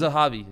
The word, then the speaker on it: Zahabi: Faraz Zabini, from TriStar. – Faraz Zahabi. – Zahabi, thank you.